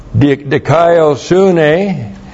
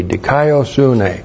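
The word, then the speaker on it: dikaiosune (δικαιοσύνη): By means of, and then we have dikaiosune, and that's the... There's the word that's the theme of the book of Romans. Looks like that in the Greek, D-I-K-A-I-O-S-U-N, long E, dikaiosune, righteousness.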